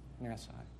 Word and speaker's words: NASA: Like, we went up down to NASA, which he corrects me on how I say that. How do you say that? NASA. Maybe I said Nassau. I don't know what I said, but he said, Dad, that's not how you say it.